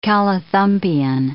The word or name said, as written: callithumpian